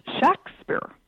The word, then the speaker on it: Shakespeare: Actually, the name was pronounced, if you look in the documents of the period, the name was pronounced Shakespeare. With a short A.